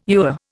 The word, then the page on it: ewer: Indian